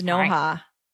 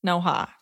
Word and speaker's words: Noha: Hi, my name is Noha, a very old Lebanese name, pronounced Noha, not Noah, like everyone likes to say. I think I'm pronouncing it right. They said pronounced N-O-H-A, Noha.